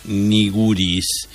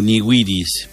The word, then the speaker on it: nigiris: Para que, como dice César, para que fuera niguris, le falta la diéresis.